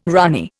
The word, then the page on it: runny: Indian